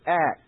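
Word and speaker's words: ak (אך): The first word coming into the psalm that is right up front is the Hebrew word ak. Just spell it A-K. We'll do good enough for English.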